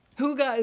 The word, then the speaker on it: Hygge: Hygge is spelled H-Y-G-G-E.